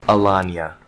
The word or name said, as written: Alanya